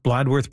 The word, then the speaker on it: Bladworth: Am I pronouncing Bladworth properly, Greg? – Yes, you are.